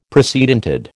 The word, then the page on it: precedented: American English